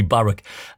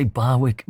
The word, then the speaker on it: Barwick: In an amazing Stuart Wink up there reporting from Ingleby Barwick, as it should be pronounced. I say Barwick, but Barwick, you know, depends which side of the road you're on.